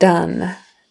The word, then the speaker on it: done: The word done, done, like I'm all done. Even though it's spelled D-O-N-E, it's got to be a short U. Done, done, done.